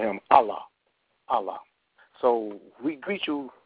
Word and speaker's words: Allah: And God, in the Arabic language, in our holy book, the name God is pronounced Allah. Allah.